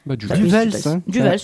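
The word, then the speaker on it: Du Welz: Donc en effet, je vais vous parler de Alléluia de Fabrice Duvels. Et je ne sais toujours pas comment ça se prononce. Hein. Si quelqu'un peut m'aider. – Duvels. C'est un Belge.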